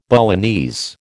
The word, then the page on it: Balinese: American English